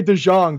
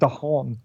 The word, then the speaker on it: DeJong: I would say DeJong, but I'm going to go on the side. It's not DeHong. – It's a soft J.